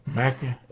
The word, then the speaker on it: Mackinac: I haven't been like I've I wanted to visit um, Mackinac Island. Is that how you say it? – Mackinac.